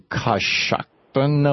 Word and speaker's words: Coshocton: Jeff in Coshocton, Ohio.